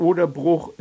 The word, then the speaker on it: Oderbruch: Früher wurde das Bruch mit langem U gesprochen, aber jetzt spricht man es meist mit kurzem U. Das Oderbruch ist 56 Kilometer lang, im Süden nur 4 Kilometer breit, im Norden aber 16.